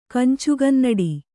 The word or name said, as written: ಕಂಚುಗನ್ನಡಿ